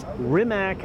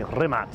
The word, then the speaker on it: Rimac: It's Rimac, I would say, in English. – Yeah, Rimac. – But most people would say Rimac. – Okay, so now, that's more kind of European pronunciation of it.